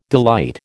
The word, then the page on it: delight: American English